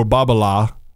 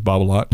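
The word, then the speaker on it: Babolat: So what Nadal did for Babala, by the way, that's correct pronunciation, even though everybody says Babalat.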